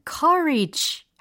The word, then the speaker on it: courage: 그래서 앞에 있는 용기에 해당하는 단어는 c, c, courage, courage 이렇게 하셔야 되고요. 두 번째 단어인 college는요. 카, 카, college 이렇게 하셔야 돼요.